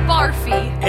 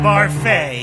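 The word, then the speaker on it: Barfée: season's tragic setback, William Barfy. – It's pronounced Barfay. There's an accent ago.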